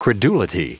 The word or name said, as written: credulity